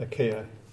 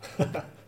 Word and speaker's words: Achaia: Achaia. Achaia. Achaia. I was going to put an R in there.